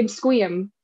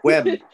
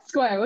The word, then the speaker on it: Sequim: How do you say Squeam? Squeam? I always say it wrong, and that's how you know I'm not from here. – Squim. – Squim, okay.